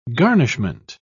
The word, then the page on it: garnishment: British English